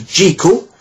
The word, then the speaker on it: Jekyll: that wrote the original Dr. Jekyll, as in treacle,